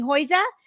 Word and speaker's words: Huyse: We have Cami Huyse joining us.